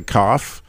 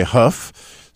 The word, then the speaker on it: Kaf: And as we talked about before the show and what got Jim all excited about the Hebrew letter, he calls it the Kaf, which makes sense because it looks like it's spelled that way, K-A-F. But if you listen to many Jewish people, you'll hear them say huf.